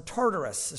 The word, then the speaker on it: Tartarus: Verse 4 of chapter 2, "For if God did not spare the angels who sinned, but cast them down to Tartarus," or Tartarus is probably the proper way to say it.